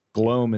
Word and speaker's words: Gloam: Glo- Gloam. – Gloam. Gloam. – I mean, Gloam. Gloam. – Yeah. Gloam is how I pronounce it, like the Gloam.